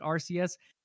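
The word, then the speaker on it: Arceus: I believe it's pronounced RCS, uh, which I believe is set to drop in May.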